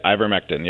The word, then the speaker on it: ivermectin: Which is not recommended by, I don't know how to say, how do you say it? – Yep, ivermectin, yep.